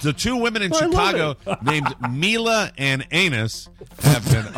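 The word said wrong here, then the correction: Anais: I think I mispronounced that. It's A-N-A-I. IS. How would one pronounce that word? – Anise, I believe. – Anise, I'm terribly sorry.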